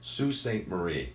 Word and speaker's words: Sault Ste. Marie: From there we go to, and this is another one when you look at the name, I am going to pronounce this correctly, but it's going to look wrong. So it's Sault Ste. Marie.